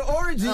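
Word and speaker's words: Orji: We have an orgy. Okay. Is that how you pronounce the last name? Orgy? – It's orgy. In Nigeria, I'm Nigerian, so it's orgy. But here it's orgy.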